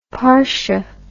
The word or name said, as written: parsha